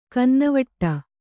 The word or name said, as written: ಕನ್ನವಿಡು